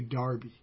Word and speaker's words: Derby: They're from, it's spelled like Derby, but they say Darby, like Crash.